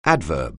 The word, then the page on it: adverb: anglais